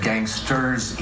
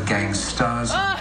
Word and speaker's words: gangsta: You said gangsters instead of gangstas, song by Coolio.